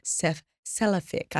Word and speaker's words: cephalic: So we have a phase of digestion called cephalic.